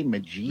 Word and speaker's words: Majid: I prefer the hard J, Majid.